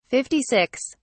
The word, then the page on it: 56: British English